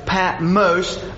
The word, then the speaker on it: Patmos: Uh, it's Patmos, uh, is how you would pronounce it. Uh, I know many people say Patmos, but how they would pronounce it would be Patmos.